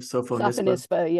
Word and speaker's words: Sophonisba: Sophonisba, is that how you say her name? – Sophonisba, yeah.